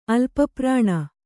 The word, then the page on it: ಅಲ್ಪಪ್ರಾಣ: alpaprāṇa